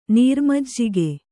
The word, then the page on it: ನೀರುಮಜ್ಜಿಗೆ: nīru majjige